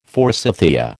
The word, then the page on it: forsythia: American English